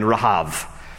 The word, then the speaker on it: Rahav: Those two spies go to the house of Rahab, or in Hebrew her name is pronounced with a V at the end, Rahav.